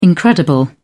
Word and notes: incredible: British English

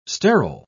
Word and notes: sterol: British English

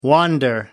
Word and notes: wander: American English

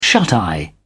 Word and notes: shuteye: British English

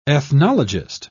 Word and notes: ethnologist: British English

